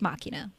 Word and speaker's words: Machina: Am I saying that right? Or is it Machina? – It's Machina.